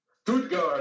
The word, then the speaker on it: Stuttgart: We have an awesome band feature all the way from, I believe I'm saying it right, Stuttgart, Germany. Is that right? – Stuttgart, yeah.